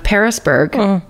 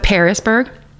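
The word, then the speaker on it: Pearisburg: Parisburg, but it's like the fruit pear, not Paris, Paris, France, but Parisburg. And I'm probably pronouncing it wrong.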